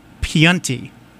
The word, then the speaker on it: Piunti: Nick Piunti. P-I-U-N-T-I. How did I do, Sue? – Sounded good to me. – Yeah? – You sold it.